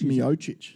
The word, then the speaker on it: Miocic: And then you got Stipe Miocic or Miocic. Miocic. – Miocic. – Miocic. That's probably a better pronunciation.